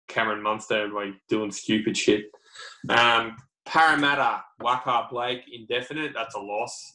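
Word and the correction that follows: Waqa: I believe it's they pronounced Wanga Blake.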